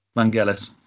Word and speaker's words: Vangelis: Vangelis.